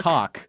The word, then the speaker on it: caulk: Like C-A, people pronounce it caulk. – It's pronounced caulk. – It's pronounced caulk. – Caulk. – Right? Like a caulking gun. C-A-U-L-K is caulk.